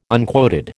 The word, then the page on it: unquoted: American English